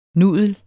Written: nudel

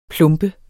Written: plump

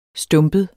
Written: stumpet

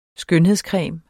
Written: skønhedscreme

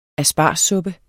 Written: aspargessuppe

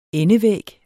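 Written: ENDEVÆG

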